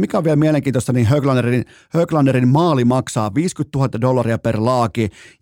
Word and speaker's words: Höglander: Miller, Brady Kachuk, Kirill Kaprizov, joten tämä antaa mun mielestä aika erikoistakin osviittaa Höglanderin suoritustasosta, tai siis Höglanderin suoritustasosta, ei missään nimessä voi ääntää jenkeittäin, vaan pitää ääntää niin kuin skandinaavilaisittain. Näin, huomaatteko, miten vaikea sanoa, tulee silti, vaikka on kurkku aivan, ei ole niin kuin kipeä, tämä kurkku ei pätkän vertaa kipeä.